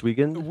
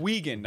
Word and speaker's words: Wiegand: Uh, yes, Chris Wiegand. Wiegand, I'm mm-hmm.